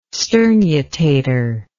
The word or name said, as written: sternutator